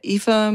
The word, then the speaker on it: Aoife: Mich fragt man in Irland immer, wie Eva mit IVE oder, weil es gibt ein gälisches Eva und das schreibt man mit A-O-I-F-E. – Na, A-O-I-F-E. Und das heißt, sagt man aber Eva, weil alles bis zum I bleibt stumm. Und das muss man erst einmal wissen. Oder der Name Niamh, das heißt Licht und das schreibt man N-I-A-M-H.